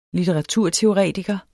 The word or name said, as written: litteraturteoretiker